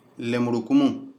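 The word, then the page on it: ߟߋ߬ߡߎ߬ߙߎ߬ߞߎߡߎ߲: lemurukumun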